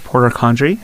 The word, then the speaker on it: Porter-Condry: We also have Candace Porter-Condry.